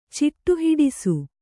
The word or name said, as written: ಚಿಟ್ಟುಹಿಡಿಸು